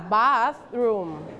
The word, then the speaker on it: bathroom: okay don't forget the th sound you have to use your tongue bathroom